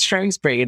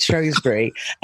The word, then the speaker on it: Shrewsbury: and she tried to pronounce it, and it was Shrewsbury. And I was like, it's Shrewsbury. And she's like, oh, right.